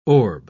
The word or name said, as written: orb